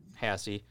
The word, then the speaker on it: Haase: Haase. I like sassy. Sassy Haase. Spelled like Haas, but pronounced Haase.